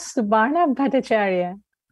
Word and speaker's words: Subarna Bhattacharyya: That is Subarna Bhattacharyya.